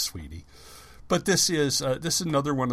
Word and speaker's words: Suibhne: Now, this one is, I believe it's pronounced Sweeney, but it looks like a transliteration of Gaelic, so I could be wrong. Suibhne.